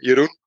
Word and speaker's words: Jeroen: I'm Jeroen. That's the way you pronounce it in Dutch, and basically every other language butchers it a bit, but that's fine.